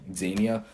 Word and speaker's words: Xenia: How do you pronounce it? It starts with an X, like Xenia. – Xenia.